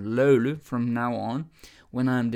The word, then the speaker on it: löyly: And they are there for löyly. Löyly, okay? Löyly is spelled L-Ö-U-L-Ö.